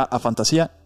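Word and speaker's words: afantasía: Creo que lo pronuncié la otra mal será a fantasía e hiperfantasía.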